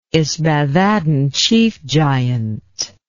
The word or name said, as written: ysbaddaden chief giant